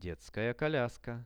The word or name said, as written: detskaja koliaska